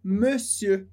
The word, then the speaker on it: monsieur: A gente vai ver numa aula de pronúncia mais pra frente o som de monsieur com mais detalhes. Mas é duas vezes esse som de e. Monsieur. Monsieur.